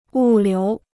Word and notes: 物流: wù liú

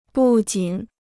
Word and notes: 不仅: bù jǐn

